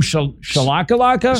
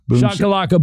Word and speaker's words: boom shakalaka: Boom shalakalaka? – Shakalaka, yeah. – Boom, shakalaka boom. – Boom shakalaka. That's the correct way to say it.